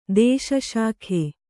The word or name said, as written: ದೇಶಶಾಖೆ